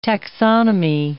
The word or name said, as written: taxonomy